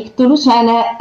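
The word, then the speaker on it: Chthulucene: in questa nota lei spiega la pronuncia del termine e dice la seguente cosa, nota che probabilmente tra l'altro spungeremo perché la pronuncia di Ctulucene e eh, anche di Ctonic in inglese dovrebbe essere con una T dolce e una K muta. Ctulucen, Tonic, quindi eliminare la C fondamentalmente dalla pronuncia.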